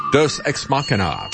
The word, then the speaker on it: Deus Ex Machina: The title is Deus Ex Machina, or actually, I, that's pronounced correctly, What it is a Latin phrase, God from the machine.